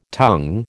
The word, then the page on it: tongue: American English